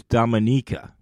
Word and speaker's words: Dominica: The correct pronunciation is Dominica. It's not Dominica. You shouldn't say the country's name as if it was the Dominican Republic. It's pronounced Dominica.